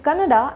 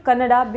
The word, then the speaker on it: Kannada: Karnataka film actor Kiccha Sudeep, in an interview, so he corrected the pronunciation of the interviewer who called Kannada, who said Kannada as Kannad. He said, if Hindi is not pronounced as Hind, then why should Kannada be pronounced as Kannad?